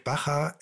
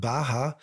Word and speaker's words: Baja: Und das wird Baja in Spanisch ausgesprochen und Baja im mexikanischen Spanisch.